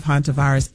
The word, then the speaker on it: Hantavirus: Hantavirus.